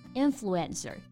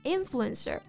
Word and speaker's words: influencer: No, it's influencer. 重音在第一个音节。 – Right, influencer.